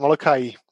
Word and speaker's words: Molokai: Is it Molokai Bound? Molokai Bound? – Good. Yeah, Molokai Bound. – Okay, thank you. Thanks. It's Molokai, is it? Molokai.